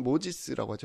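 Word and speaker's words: Moses: 아, 그 발음으로는 모지스라고 하죠. 모지스.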